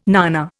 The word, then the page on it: nana: Indian